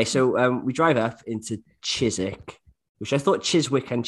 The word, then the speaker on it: Chiswick: We were in this place called Chiswick, but apparently it's Chiswick.